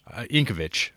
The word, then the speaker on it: Yankovic: Vich or Vic? – Yankovic. That's how I've always said it.